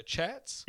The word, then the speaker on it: The Chats: The chats. – Yeah, the Chats. – I'll definitely write that down. Is it how it's spelled, how it sounds? – Like us, we're chatting right now.